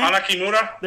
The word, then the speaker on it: Hana Kimura: Kimori, Kimura. How do you pronounce it? – The girl's name. Hana Kimura.